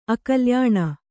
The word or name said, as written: ಅಕಲ್ಯಾಣ